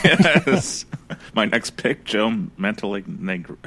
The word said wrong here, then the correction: Manganello: I can't even say his name. – Joe Mangello, or whatever his name is. Yeah. – Manganello. Manganello, yes. That's it. Joe Manganello.